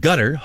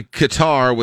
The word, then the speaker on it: Qatar: There is zero chance that you or me is going to be able to do the proper Arabic Qatar.